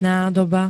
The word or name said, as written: nádoba